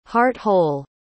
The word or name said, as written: heart-whole